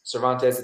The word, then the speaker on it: Cervantes: That's @Cervantes1. Cervantes, it's like a play on my last name.